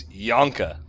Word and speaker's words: Yonka: I was like, Junka. – Yeah. – Unfortunate. – Her name is Junka. – It's Yonka. – Yeah, if you want to pronounce it that way, sure.